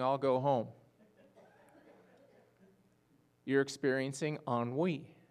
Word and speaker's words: ennui: You spell it. You can go home and look this up. E-N-N-U-I. Right? Anybody know that word? It's pronounced ennui.